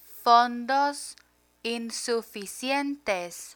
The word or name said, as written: fondos insuficientes